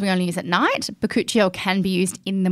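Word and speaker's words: bakuchiol: But a fantastic ingredient, bacuchiol, which Kelly struggles to pronounce. How do you pronounce it? – Bacuchiol. – Yeah, you did it.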